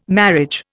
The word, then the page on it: marriage: Indian